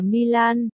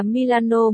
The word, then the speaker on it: Milano: Không, không phải là Milan, phải đọc là Milano mới đúng.